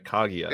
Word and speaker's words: Kaguya: Kaguya. I'm saying that right. Kaguya. – There we go. We did it.